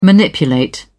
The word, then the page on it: manipulate: British English